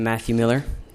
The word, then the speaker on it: Matthew Miller: I'm Matthew Miller. That's spelled M-A-T-T-H-E-W-M-I-L-L-E-R.